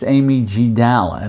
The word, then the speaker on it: amygdala: But let's get back to stress the stress response is initiated by the amygdala, pronounced amygdala, and this is part of the brain that's responsible for our basically our instinct.